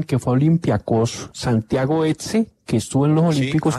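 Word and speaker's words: Hezze: Este es volante de marca, es un mediocentro volante de marca, Santiago Etze. Se escribe H-E-Z-E, que era el Olympiacos.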